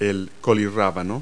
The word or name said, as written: kohlrabi